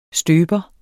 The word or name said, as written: støber